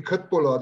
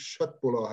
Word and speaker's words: Köttbullar: Das ist sowas wie Köttbullar, die eigentlich Schöttbullar heißen.